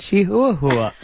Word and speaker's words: chihuahua: And I said, oh, a chihuahua. And she goes, in Spanish, it's pronounced chihuahua.